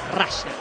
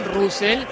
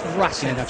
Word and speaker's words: Russell: No, pronunciación la real es Russell. – Sí. – Muchos le dicen Russell, está perfecto, pero la pronunciación correcta es Russell.